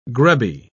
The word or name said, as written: grubby